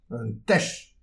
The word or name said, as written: un tech